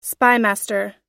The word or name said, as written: spymaster